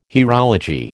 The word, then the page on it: hierology: American English